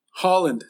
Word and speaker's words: Haaland: Haaland. Like the country Haaland.